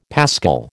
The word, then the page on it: paschal: American English